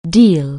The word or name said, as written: deal